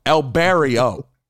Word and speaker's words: El Barrio: It's the L, so it's Spanish. – Yeah. – But it's not Barrio. It's just El Barrio. – How it's spelled, baby? – It's like El Barry Obama. El Barrio.